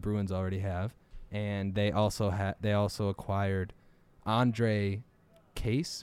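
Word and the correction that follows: Kaše: Kasha. Yeah, that's how you say it.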